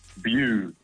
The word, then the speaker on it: Bew: Bew. It's like blue without the L.